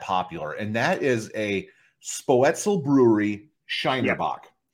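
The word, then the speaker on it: Spoetzl: And they basically broke it down to say an S sound and then say poet and then zeal. – Okay. – So Spoetzel, something like that.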